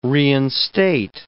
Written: reinstate

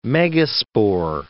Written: megaspore

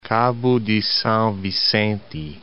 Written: Cabo de Sao Vicente

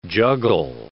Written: juggle

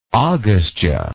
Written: agastya

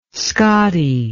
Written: Scotty

